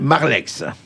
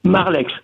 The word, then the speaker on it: Marleix: Olivier Marleix. – Marleix.